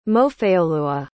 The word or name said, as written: mofeoluwa